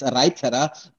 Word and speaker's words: Reiterer: In the place where I'm coming from, it's Reiterer, but I'm used to hear it that way.